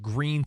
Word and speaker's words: Gruene: No, I swear, when my wife's from New Braunfels, Texas, and there's a really cool little town called Green, Texas. It looks like it's spelled Gruen, G-R-U-E-N-E.